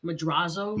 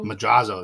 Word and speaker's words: Madrazo: There's actually a scene with Michael when he meets Mr. Mazzura. – Mm-hmm. – Is that how you say his name? – Madrazo. – Madrazo, thank you.